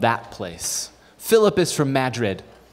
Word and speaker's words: Madrid: I looked up on the census in Madrid. Yeah, apparently that's how you say it.